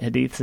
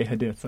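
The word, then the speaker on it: Hadith: It's funny because I was pronouncing that Hadiths and then someone corrected me at a different station. I'm like, okay, Hadiths, I'm sorry.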